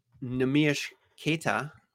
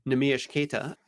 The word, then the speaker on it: Neemias Queta: I was under the impression that Neemias Queta, which is how you pronounce his name, Neemias Queta,